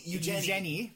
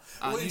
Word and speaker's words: Eugenie: Eugenie, it's the French pronunciation, as she specifically points out. – Eugenie, she like going by Jenny.